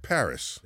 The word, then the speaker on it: Parris: We got Colin Parris. I pronounced that last name right. Yes, you did. Just Paris. That's right. Like the city. Like the city. So you have you got two R's.